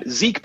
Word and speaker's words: Zeke: Zeke, by the way, is spelled Z-E-K-E. Thomas, T-H-O, M like Mary, A like Apple, S like Sam.